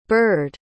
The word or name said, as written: burred